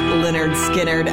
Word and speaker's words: Lynyrd Skynyrd: Freebird from their, of course, debut album, pronounced Lynyrd Skynyrd. I will say, though, that this year's Super Bowl commercial with Budweiser was pretty awesome. It was pretty awesome. Freebird, Lynyrd Skynyrd, Double Shot Lunch, Classic Rock 97 LAV.